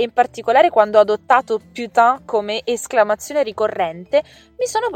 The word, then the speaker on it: putain: Ha ah, una piccola curiosità fonetica riguardo la parola putain Dunque, putain, come dicevo prima, si scrive putain, P-U-T-A-I-N, e la parte finale, T-A-I-N, si pronuncia con questo suono che sembra una A molto nasale, quindi tin, putain.